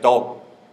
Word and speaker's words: Tao: Etymologically speaking, the character Tao, probably pronounced something like Dao in archaic and ancient Chinese, consists of tuo and shou, so to walk and head.